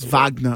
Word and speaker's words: Wagner: I guess, but you say he's German, so it's Wagner.